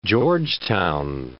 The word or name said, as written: George Town